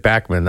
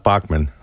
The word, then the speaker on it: Bachman: Saw them way back in 2001, and because of the fact that they have Burton Cummings and Randy Bachman, and that's how you say it, Bachman, not Bachman, it's going to be a great show, I'm sure.